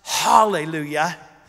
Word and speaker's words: Hallelujah: By the way, in Hebrew, it's pronounced Hallelujah, but it's spelled Alleluia.